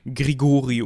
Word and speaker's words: Grigori: Erstmal muss man festhalten, dass der gute Rasputin eigentlich Grigori Jefimowitsch Rasputin heißt. Ja, also Falls es da mal vorkommen sollte, dass wir ihn nicht Rasputin, sondern Grigori oder so nennen, dann wisst ihr, jetzt muss ich ja sagen, ich glaube, das wird nicht vorkommen.